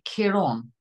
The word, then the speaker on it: Ciaran: because apparently it should be Ciaran, a bit like a yawn, Ciaran. – Yes, I was explained it should be key and then yawn without the Y, and the emphasis is on the orn, so it's Ciaran, but it doesn't sound right.